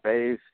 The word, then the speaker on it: Bays: Jeffrey Michael Bays I'm sure you'll find something.